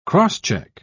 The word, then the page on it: cross-check: British English